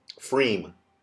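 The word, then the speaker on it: pFriem: Freem. – Frame.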